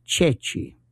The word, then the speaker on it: ceci: One of them was that during the war, they would sometimes hold up a chickpea, and it's pronounced Ceci, C-E-C-I. But French could not pronounce that.